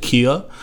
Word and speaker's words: Keuh: That's how you pronounce it, Kia. – Like a Kia Soul, like the like, yeah, like the Kia, car. But it's spelled K-E-U-H.